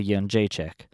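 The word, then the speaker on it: Jędrzejczyk: If you don't know how to pronounce her name, you're in for some trouble because you cannot call her Joanna Champion anymore. It's Joanna Janjacek.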